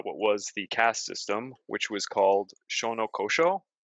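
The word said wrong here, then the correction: Shinōkōshō: and I actually have everything written here. Uh, Shino Koshō. I already mispronounced it.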